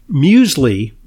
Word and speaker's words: Musely: Now for Muesli, which is not it's a play on words. It's not Muesli like, you know, the breakfast cereal from Switzerland. – Not like the cereal. – Right. It's spelled M-U-S-E-L-Y.